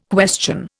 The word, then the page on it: question: Indian